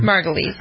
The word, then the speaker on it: Margulies: How do you pronounce her name? – Margulies.